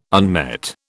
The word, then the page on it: unmet: British English